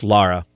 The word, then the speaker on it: Laura: Spells it Laura, but it's pronounced Lara.